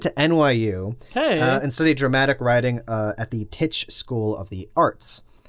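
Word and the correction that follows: Tisch: It's pronounced Tish. – Tish? – Mm-hmm. – What did I say? – Titch. – Did I say Titch? I meant to say Tish.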